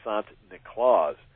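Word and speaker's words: Sint Niklaas: And then, of course, the Dutch immigrants brought him to New Amsterdam, which became New York, and the Dutch pronounced St. Nicholas Sant Niklaus. And that's where we get the Santa Claus.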